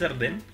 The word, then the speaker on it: Dardenne: Yo sin haber visto nada de los Dardien, pero estando más o menos... ¿Dardien o Darden? Eh, Adam dice Dardenne, creo.